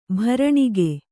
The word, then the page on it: ಭರಣಿಗೆ: bharaṇige